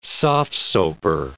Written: soft-soaper

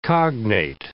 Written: cognate

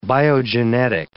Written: biogenetic

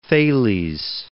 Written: Thales

